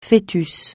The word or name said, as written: fœtus